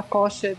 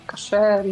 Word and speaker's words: kosher: Eu que você tá falando de, do kosher. – Isso, é, porque pode. Não, kosher, desculpa. – Sim, aqui, aqui a gente fala, a gente fala kosher, né? Mas é kosher, enfim.